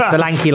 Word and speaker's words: Solanke: It is like Solanke like a donkey. – What? – Because it rhymes.